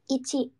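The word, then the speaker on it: Ichi (いち): Ichi.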